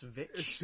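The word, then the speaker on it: VVitch: I believe it's pronounced Vitch.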